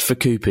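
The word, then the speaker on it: Fukuppy: Although, to be fair, it is pronounced Fukupi, isn't it, in Japanese? So it's just, but it does look like Fuck Uppy.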